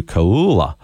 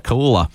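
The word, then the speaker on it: Koula: If you didn't catch it, there was a tackle that occurred with a Queensland player, Kalen Ponga, clashing with Blues debutant Tolu Kaula. I butchered that, Kaula.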